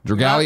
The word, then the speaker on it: Dragalia: Am I saying it right, Kevin? Dragalia. – Yeah, you nailed it.